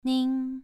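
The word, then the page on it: nin: tone 2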